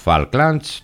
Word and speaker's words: Falklands: Μπορεί να μην είχαν ξεχάσει τα Falklands στην Αργεντινή. Γράφονται με A και προφέρονται O.